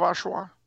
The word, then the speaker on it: Oshawa: Oshawa. Oshawa isn't a what, it's a where. It's a town on the lake a bit east of Toronto. – Okay. – Oshawa. You've never heard of Oshawa? – No.